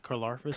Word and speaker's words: Karlaftis: George Karlaftis. – Karlaftis, is that how you say that? – Sounds like a syndrome.